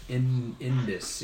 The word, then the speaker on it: Indus: Indus. – Yeah, I didn't ask her to spell it. – Okay. – But that's roughly the pronunciation.